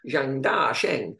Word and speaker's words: Zhang Dasheng: because with the Chinese names, you have to say it like Zhang Dasheng is how it's written, but it's up or down. So actually, it's Zhang Dasheng, if you say it correctly, but you can't say it correctly as an Englishman in the middle of a commentary flow.